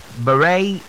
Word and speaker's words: beret: All right, so we have the other one, and that has the word, I have pronounced this word before, and that is the word beret. Beret is not beret. Okay, so you put on the beret church. So it's beret. One more time, beret. That's the way it's pronounced. And the last word for today is the word jewelry. Jewelry, not jewelry. J-E-W-E-L-O-R-Y.